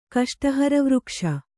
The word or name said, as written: ಕಷ್ಟಹರವೃಕ್ಷ